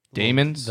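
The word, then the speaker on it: daemon: Demons? – Yeah, demons. – Yeah. – The little animals. – Like, I think in the books it's spelled like D-A-E. – But you still call it a demon.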